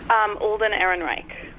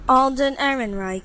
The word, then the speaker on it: Alden Ehrenreich: Alden Ehrenreich. – Right, we've YouTubed it. – Alden Ehrenreich.